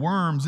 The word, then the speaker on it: Worms: And worms isn't really pronounced worms unless you're from Red Bank, South Carolina. It's a German city or German town pronounced Worms.